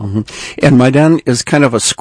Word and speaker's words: Maidan: How do you pronounce it? – Maidan. – Maidan.